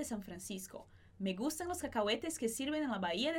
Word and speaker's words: cacahuetes: O H não vai ser pronunciado, então cacahuetes que sirven en la.